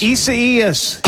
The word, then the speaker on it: Isaias: Isaias. – Isaias.